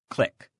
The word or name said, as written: click